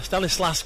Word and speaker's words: Stanislas: When Stanislav got the equaliser, I keep calling him Stanislav, when it's an S on the end, Stanislas got the equaliser against Everton.